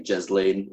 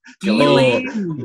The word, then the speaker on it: Ghislaine: I've been saying Jez Lane. I've been calling her Jez Lane Maxwell. Ghislaine. Ghislaine.